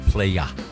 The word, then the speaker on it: playa: I believe it's pronounced playa. – I did say playa.